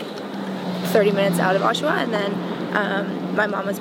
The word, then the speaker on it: Oshawa: So you're born in, how do you say it, Oshawa or? – Oshawa. Oshawa, yes. Mm-hmm.